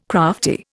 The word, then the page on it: crafty: Indian